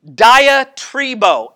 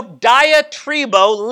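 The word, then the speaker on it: diatribo (διατρίβω): The word for spend time in the Greek here is pronounced diatribo. And dia means against, and tribo means to rub. Okay, so diatribo literally means to rub against or to rub off on.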